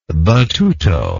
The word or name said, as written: battuto